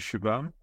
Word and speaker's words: Shubham: Nice to meet you, Shubham.